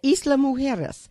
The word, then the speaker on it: Isla Mujeres: We were thinking about Playa, Cancun, or even, is it pronounced Isla Mujeres, the island outside of Cancun? – Yeah, Isla Mujeres.